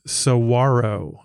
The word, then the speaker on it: saguaro: And Luke helped me with the pronunciation because we weren't sure what they were called. I believe it's saguaro. I believe that's how you say these amazing giant 40-foot cactuses that are out there.